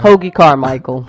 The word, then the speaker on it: Hoagy Carmichael: Hoagy Carmichael.